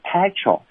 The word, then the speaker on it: petrol: 但是大家呢要注意了哈，当它简读的时候呢，要读成的是 petrol。但是呢，它是这个原词啊，你要把它读成的叫做 petroleum 啊，不要读成什么 petroleum 啊，这个发音大家一定要注意一下。